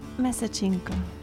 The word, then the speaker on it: Месечинка: Месечинка. – You see, not that bad. It rhymes, месечинка rhymes with палинка.